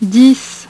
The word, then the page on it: dix: French